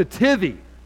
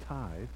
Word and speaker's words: tithe: And, so he said, what's the tithy? And I said, well, you know, it's pronounced tithe.